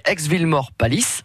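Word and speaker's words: Aix-Villemaur-Pâlis: Et puis une dernière commune que l'on peut citer, aix villemort palisse j'espère que je prononce bien. – Tout à fait, c'est bien ça.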